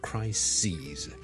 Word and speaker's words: crises: 留意個發音啊，串字當然係唔同啦，但係發音係唔同嘅，crisis 同 crises。